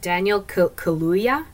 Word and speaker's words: Kaluuya: Kayuk, am I saying that right? Hold on. Daniel Kaluuya.